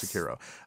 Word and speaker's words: Sekiro: Sekiro. – Yes. – Sekiro.